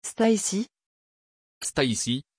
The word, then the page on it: Stacie: French